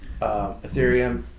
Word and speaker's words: Ethereum: But if that doesn't go down, then I think you're going to see Ethereum.